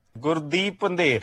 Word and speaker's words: Gurdeep Pandher: So here is the proper pronunciation from the gentleman himself. – Gurdeep Pandher. – Do you want to hear it again? – No, I got it. Gurdeep Pandher.